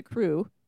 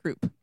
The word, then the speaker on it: croup: However, whoever, I have always pronounced it crew because I read it like coo plus an R, but then everyone at the hospital was saying croup.